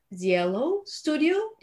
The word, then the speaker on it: Zielo Studio: And today I'm joined by a fellow mom who is the founder and CEO of Zielo Studio.